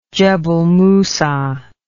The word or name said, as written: Jebel Musa